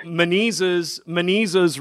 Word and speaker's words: Menezes: You know, I tell people, Menezes rhymes with diseases, right? – Oh, so my goodness. – Once I tell people that, well, the funny thing is, once I tell people that, they never, ever forget how to pronounce Menezes.